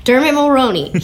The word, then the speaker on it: Dermot Mulroney: Dermot Mulroney.